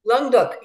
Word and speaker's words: Languedoc: I think it's the Languedoc. Am I pronouncing? – Yes. Languedoc.